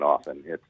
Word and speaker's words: often: She says, thanks for pronouncing it with a silent T. That's from Mary. – Well, you're welcome, Mary. I will never pronounce the T in often. It's important to me to keep it silent.